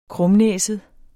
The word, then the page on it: krumnæset: ˈkʁɔmˌnεˀsəð